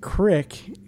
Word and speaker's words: creek: Well, first of all, I think you guys are mispronouncing it. They pronounce it crick in the documentary.